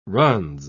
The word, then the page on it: runs: British English